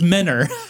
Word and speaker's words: Mentor: Mentor spelled N-E. It's pronounced Menor. I knew it. – People, I'm going to spell this for you. Please be on my side. Mentor. – And that's literally how you spell the word mentor.